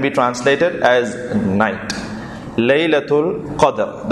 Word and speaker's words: Qadr: Firstly, the term Qadr, The term Qadr can be pronounced in two ways. One is that you can pronounce it as Qadr with a fatha over the dal, Qadr, Qadr.